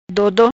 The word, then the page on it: Dodo: Patua